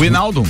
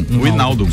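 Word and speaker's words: Wijnaldum: Leonel Messi, eh, Donnarumma, Sérgio Ramos e esse que a gente nunca consegue pronunciar o nome lá do Liverpool, Vinaldum, deve ser isso. Né? Winaldum. Winaldum. – Winaldum.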